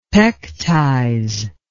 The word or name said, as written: pectize